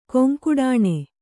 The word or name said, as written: ಕೊಂಕುಡಾಣೆ